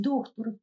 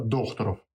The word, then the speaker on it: Дохтуров: Поэтому в правильной фамилии будет все-таки, наверное, Докторов. – Ну, в любом случае, э, ударение в фамилии не считается за ошибку. Ну, будем считать, что мы говорим правильно и говорим мы о Дмитрии Сергеевиче Докторове.